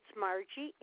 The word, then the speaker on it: Mergie: It's Margie, M-E-R-G-I-E. The last name is K-E-S-K-I-M-A-K-I.